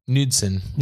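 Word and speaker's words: Knudsen: Am I saying that right? Jens Nunsen. – Nudsen. – Nudsen, like the cottage cheese.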